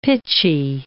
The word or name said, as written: pitchy